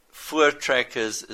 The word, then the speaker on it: Voortrekkers: And Voortrekkers is spelt with a V, but it's pronounced as an F.